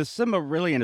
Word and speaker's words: Silmarillion: But the Silmarillion, is that how you say it? – Yeah.